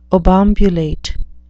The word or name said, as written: obambulate